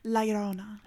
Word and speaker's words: Llorona: Pronounce it for me one more time. – Llorona. I can't roll my R's, but I can say that word.